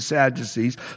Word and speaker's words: Sadducee: And so it says, along with all of his associates, that is the sect of the Sadducees. So this is the liberal ruling party. Of course, you know, we know Sadducee, you know how you pronounce it, sad, you see.